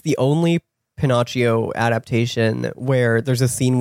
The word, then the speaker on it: Pinocchio: And then my other friend had heard me say Pinocchio and was like, actually, the proper Italian pronunciation is Pinocchio.